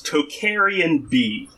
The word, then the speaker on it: Tocharian: early medieval period was Tocharian B. Have you heard of Tocharian B? – I have not. – Probably saying it wrong. It's T-O-C-H-A-R-I-A-N.